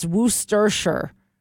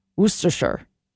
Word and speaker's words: Worcestershire: Did I get it close? Dave says it's Worcestershire. Worcestershire. You hear Worcestershire.